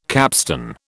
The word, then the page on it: capstone: British English